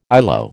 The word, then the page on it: ILO: American English